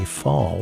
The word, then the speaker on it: Falle: That's new music from Marty Fall, which is spelled F-A-L-L-E.